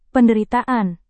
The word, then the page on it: penderitaan: Indonesian